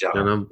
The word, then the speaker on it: Janam: I often pronounce it Janam, but is it, you're correct that it's Janam. – Janam, okay, makes sense.